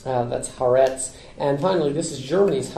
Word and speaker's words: Haaretz: It's pronounced Haaretz.